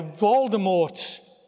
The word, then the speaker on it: Voldemort: If you've read any of the books or seen any of the movies, you'll be aware, no doubt, of the bad guy, Voldemort, who apparently is correctly pronounced with a silent T at the end.